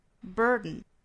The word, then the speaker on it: burden: 我们注意前面有一个 r 的卷舌音。 burden. 那鼻腔爆破只是本族人他比较习惯的一种发音哈。相当于是用鼻子去呛这么一下。